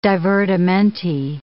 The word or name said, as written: divertimenti